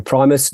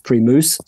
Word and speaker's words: Primus: So I write a little scene with someone in a bar ordering a Primus. No, it's pronounced Primus, says the bartender.